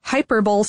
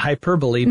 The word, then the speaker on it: Hyperbole: So this one was developed by, and I think this is how you pronounce it, Hyperbol Studios. – Oh, not hyperbole. – Not hyperbole. Yeah, Hyperbol. – Okay. – That's how it's spelled.